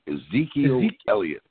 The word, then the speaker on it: Ezekiel Elliott: And when you got Ezekiel Elliott.